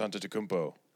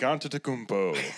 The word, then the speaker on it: Antetokounmpo: Now you have good nicknames like fucking like the Greek Freak, Giannis Antetokounmpo.